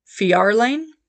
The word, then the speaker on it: Fjarlane: And the Fjord Farlane, I think I said that right, FJARLAN, So wait, hang on. So Fjord is also FJ, so that should be Fjarlane. That sounds better.